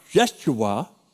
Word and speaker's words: Yeshua: You know, it's so common that in the English language, you know, in the Hebrew, it's pronounced Yeshua.